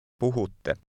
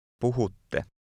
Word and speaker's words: Puhutte: Puhutte. – Pay close attention to the double T sound at the end. Say again, you speak. – Puhutte. Puhutte.